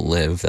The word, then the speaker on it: Liv.e: Shout out Liv, that's how you pronounce that name, LIV.E.